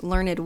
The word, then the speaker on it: learned: I chose the name, the name of this podcast, Learned Woman, because if you Google learned, and yes, that is how it is pronounced, at least according to Google, it will say showing, requiring, or characterized by learning.